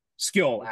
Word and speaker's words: Skoll: S-K-O-L. – Okay, yep. Yeah. S-K-O-L-L. Like Hati and Skill. Skill actually would be the pronunciation.